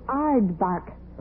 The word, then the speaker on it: aardvark: 30 grand just for knowing a simple thing like what is a yard mark. What ignorance. – Look who's talking. You can't even pronounce it. It's aardvark.